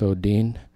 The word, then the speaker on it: odin: It's spelled O-D-I-N, like the Norse god, but it's pronounced Odin.